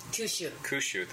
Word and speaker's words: Kyushu: Kyushu, that's how it's pronounced.